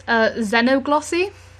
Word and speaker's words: Xenoglossy: I believe it's Xenoglossy. – I'm going to say yes. – Yeah? – How do you spell that? – X-E-N-O-Glossy. – I'd call it Xenoglossy.